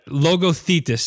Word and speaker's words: Logothetis: but it's Mr. Leon Lothitis. I butchered it. I know I did. you kind of butchered it, but don't worry about it. It's Logothetis. – Logothetis.